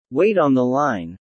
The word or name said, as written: wait on the line